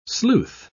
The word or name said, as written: sleuth